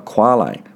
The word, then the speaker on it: quale: A typical such puzzle is that of qualia, singular quale, which rhymes with barley, meaning the subjective aspect of sensations.